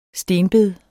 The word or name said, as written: stenbed